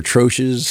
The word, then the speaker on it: troche: Troches, like, I think I think actually the way people say it, but I think if you look it up, I think it's from the French. I think you're supposed to say troche. – Something like that. Yeah. Okay. The common pronunciation is troche, so okay, let's just stick with that.